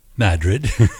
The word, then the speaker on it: New Madrid: New Madrid, or as the locals say, Madrid.